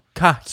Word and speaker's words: Cajiao: Cajiao. – So it's like Ka, like California, and then He, like He-Man, and then Ow, like somebody just pinched you. So Cajiao.